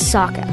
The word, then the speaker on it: Sokka: My name is Sokka. It's pronounced with an aka.